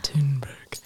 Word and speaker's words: Thunberg: Is that how you say her name? Greta Thunberg? That's how NPR says it. – NPR is Thunberg, always right. – I could be wrong. – They say it with like a Thunberg.